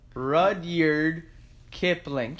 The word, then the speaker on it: Rudyard Kipling: How do you say this motherfucker's name? Rudyard Kipling. Yeah, that's how you say his name.